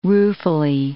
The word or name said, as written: ruefully